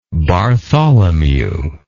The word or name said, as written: Bartholomew